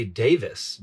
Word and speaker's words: Davies: No, it's weird that they say Davis, because it's D-A-V-I-E-S. – Oh, it's spelled Davies. – Yes. – But it's pronounced Davis.